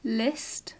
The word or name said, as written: list